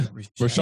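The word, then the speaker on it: Rachaad: How do you say that? Rashad? – Yeah, Rashad. Yeah, I think so. – Rashad. Okay, cool.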